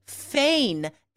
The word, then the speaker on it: feign: It's spelled like rain, right? F-E-I-G-N. It really does not look like it's pronounced, so that's tricky.